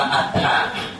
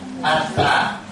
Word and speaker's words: Attha: Cái chữ này là đọc là Attha. Attha. Attha nghĩa là nghĩa, ý nghĩa.